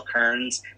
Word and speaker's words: Kearns: Kearns. My last name is spelled K-E-A-R-N-S.